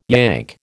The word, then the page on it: Yank: American English